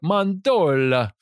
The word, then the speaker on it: mandorla: It's the Italian word for almond. So I guess you have to say it like mandorla, right?